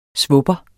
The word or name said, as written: svupper